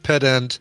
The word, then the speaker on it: pedant: So, Zoe says it's pronounced pedant, even emphasis on both syllables.